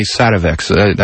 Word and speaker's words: Sativex: Well, you know, the federal government is having to do a really interesting dance on this question of, by the way, I've never been sure if the correct pronunciation is Sativex or Sativex. – Well, the founder, Jeffrey Guy, told me Sativex. I think that's a British pronunciation.